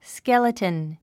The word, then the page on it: skeleton: skélətn; スケルトゥン